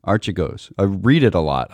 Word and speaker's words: Archegos: I have been saying Archegos, which seems quite appropriate in that their egos seemed to be pretty arch until they collapsed.